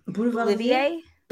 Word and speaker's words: Boulevardier: Boulevardier? – Yeah, but it's really good.